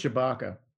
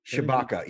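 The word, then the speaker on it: Tshibaka: Did I say that right? – I think it's Shibaka. – Shibaka, yeah, you're right.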